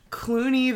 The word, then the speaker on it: Cluny: Clooney the Scourge, who is a giant rat, is... – a giant rat with tattoos. And also, I just want to say, it's spelled C-L-U-N-Y, but obviously pronounced Clooney.